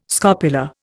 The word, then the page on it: scopula: Indian